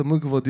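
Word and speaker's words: Mgła: Mıgla diye bir MGLA diye yazılıyor da Mıgva diye okunuyor.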